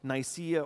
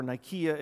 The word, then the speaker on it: Nicaea: Because the Nicene Creed is built off of the Apostles' Creed and also the Council of Nicaea or Nicaea, if you're more of a stickler on Greek pronunciation.